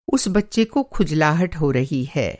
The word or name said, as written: खुजलाहट